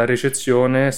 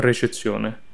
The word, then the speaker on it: recezione: Esatto, sappiamo che la recezione sarà... Ricezione o recezione? – Recezione. – Recezione, ok, avevo detto bene.